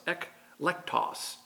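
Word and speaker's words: eklektos (ἐκλεκτός): The word for choice is ekleketos, which is pronounced eklektos in the Greek.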